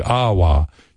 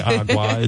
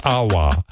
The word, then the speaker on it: agua: It's like Awa. You don't say Agua. It's Awa. Or Sabotage. – Tsunami. Sabotage. Tsunami. All right. The signs of greatness were all there. – Awa. – Awa. Awa.